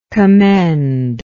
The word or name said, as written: commendingly